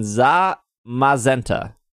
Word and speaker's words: Zamazenta: Zacianna and Zamazenta, I believe is how you say them. I think I did okay. – There's no A at the end of the Zacian. – Oh, Zacian? Sorry. Zacian and Zamazenta.